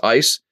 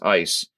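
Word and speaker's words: ice (εἰς): So the Greek word is the word, it, actually is pronounced ice. Okay. Epsilon, iota, sigma. And it's three letters in the Greek language, and it's pronounced ice.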